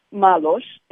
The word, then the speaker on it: Malos: So my name is spelled R-O-D-I-C-A, Malos, M-A-L-O-S, .com.